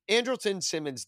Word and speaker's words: Andrelton: Anderson, uh, um, eh, um, Andrelton Simmons does not.